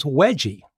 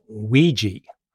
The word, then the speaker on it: Wegie: It is commonly referred to with the nickname spelled W-E-G-I-E. Now, I always thought that was Wedgie, which sounds nice and warm and fuzzy. It turns out, however, that the correct pronunciation is Weegee, which I now know and which we got correct in the book.